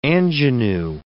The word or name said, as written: ingenue